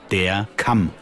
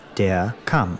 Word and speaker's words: Kamm: The word for comb is spelled K-A-M-M, double M, so it has a short vowel. Der Kamm. – Der Kamm. – Yes, der Kamm.